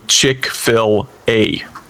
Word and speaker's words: Chick-fil-A: So this is a 1991 arcade game by a little known company called Sun A, who I believe is related to Chick-fil-A. Is that how you pronounce the fried chicken franchise? – Yes.